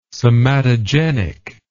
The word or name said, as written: somatogenic